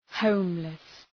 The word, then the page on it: homeless: ‘həʋmlıs